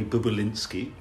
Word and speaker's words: Bobulinski: Roger, we're going to be talking about Joe Biden, this story of Tony Bobulinski, I'm pronouncing that right.